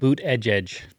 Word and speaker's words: Buttigieg: I know you sent me a tweet from Peter Buttigieg. Is that, did I say his name right? – Buttigieg.